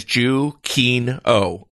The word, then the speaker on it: Giacchino: He actually tweeted it at one point. Pronunciation of my name is Giacchino. – Giacchino. Okay. Huh, that's interesting. It's not how you would say it in Italian, that's for sure.